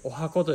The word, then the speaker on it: 十八番: 18 番と書いてお箱と読みます